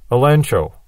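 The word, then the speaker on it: elencho (ἐλέγχω): Now, tell him his fault is in Greek, elencho. And that is spelled E-L-E-G-C-H-O. And yet the Greeks were utterly unable to pronounce G-C-H. And so they would pronounce G-C-H as if it was N-C-H, elencho.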